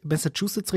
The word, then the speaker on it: Massachusetts: Nochmal, jetzt habe ich Massachusetts richtig ausgesprochen und dann kommt man durcheinander.